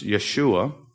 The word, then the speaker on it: Yeshua: I mean, it says Jesus in the English, but that's our pronunciation. It's Yeshua. It's a popular Jewish name, Joshua.